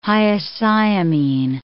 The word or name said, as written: hyoscyamine